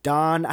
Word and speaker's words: Dan: So his sons are named Reuben, Simeon, Levi, Judah, Don. I know it's Don, but it's spelled D-A-N, and I love the idea of there being a character who's just named Dan.